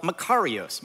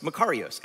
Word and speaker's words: makarios: makarios, makarios. And if you say it wrong, it sounds like macarenios or something,